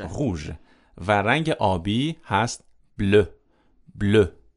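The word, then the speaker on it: bleue: فرم فمینن در میان درست هرچند که برای بلو یک ا در انتهای لغت اضافه میشه ولی تلفظ تغییر نمیده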